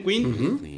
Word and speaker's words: Queen: Si lo pronuncias bien, Queen.